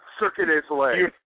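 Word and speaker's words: Cirque du Soleil: That was Circus de Soleil. I don't even know how it's pronounced. Cirque de Soleil.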